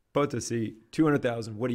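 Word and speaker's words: Potosí: Potosi. – Potosi.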